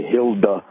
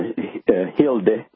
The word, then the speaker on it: Hilde: Yeah, and it's in the United States we pronounce it kind of ugly. You say Hilda, and then in Europe, Scandinavia, it's more Hilde.